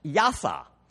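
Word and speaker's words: yasah: In the Old Testament, the Hebrew word to save is pronounced yasa. That's Y-A-S-A-H is the transliteration, yasa.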